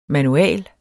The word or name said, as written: MANUALET